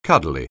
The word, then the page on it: cuddly: anglais